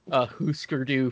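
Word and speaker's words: Hüsker Dü: So my uncle is a huge Husker Du fan. – I'm glad you're saying it correctly. You gotta respect the umlauts. – Yeah, the umlauts.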